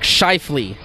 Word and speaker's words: Scheifele: Mark Scheifele. – You said that right.